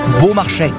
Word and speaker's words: Beaumarchais: le domaine du Beaumarchais, qu'on prononce Beaumarchais.